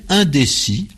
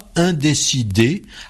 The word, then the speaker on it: indécis: Alors, on dit indécis avec un S à la fin qu'on ne prononce pas. On ne dit pas indécidé, alors que ce pourrait être la forme la plus attendue, la plus logique.